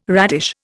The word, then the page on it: radish: Indian